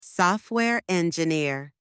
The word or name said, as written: software engineer